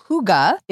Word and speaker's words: hygge: Kurgis is on a mission to bring comfort, coziness, and hygge to your feet. – Wait, what? Hygge? – Yes. Okay. So hygge is a Scandinavian concept. It is spelled H-Y-G-G-E.